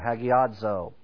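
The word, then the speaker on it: hagiadzo (ἁγιάζω): And it's spelled H-A-G-I-A-Z-O, hagiadzo.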